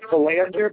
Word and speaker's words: Belanger: So I think officially it's Belanger, but he'll answer to just about anything.